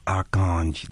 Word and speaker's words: Archange: Archange, that's how it's pronounced.